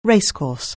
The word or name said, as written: racecourse